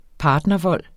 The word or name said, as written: partnervold